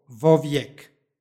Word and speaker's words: vovijek: stihu imamo predloško-padešku sintagmu u kojoj je prilikom izgovora dodato izgovorno o, vovijek. U 46. stihu isto imamo i u 20. stihu u predloško-padeškoj sintagmi vovidjeniji. I u 29. stihu imamo predloško-padešku sintagmu u kojoj je prilikom izgovora dodato izgovorno o, vovijek.